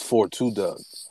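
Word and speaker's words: 42 Dugg: It's 42 Doug. – Oh, see, I'm saying it wrong.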